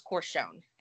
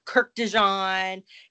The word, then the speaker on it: Kurkdjian: And yeah, it's perfumer, it's from Maison Francis Corchon. And I looked that name up because I had been butchering this dude's last name. I've been calling him Kirk Dijon because it's spelled KURKDJIAN.